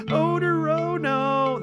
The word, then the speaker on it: Odorono: Odorono. That's exactly. But it's spelled O-D-O-R-O-N-O. So it's just like odor. Oh, no.